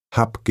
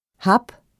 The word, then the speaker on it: Hab: Am Wortende spricht man das B härter aus. Dann klingt es fast wie ein P. Zum Beispiel: Hab Geduld. Bleib bei mir. Der Urlaub. – Hab oder bleib.